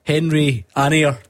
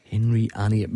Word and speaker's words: Henry Anier: Henry Anier to people in this part of the world, for the correct pronunciation. Henry Anier.